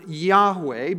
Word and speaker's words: Yahweh: We pronounce it Yahweh,